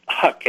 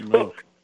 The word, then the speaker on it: Huk: It's Huck, H-U-K, which I found out is not the right pronunciation. It's Hook.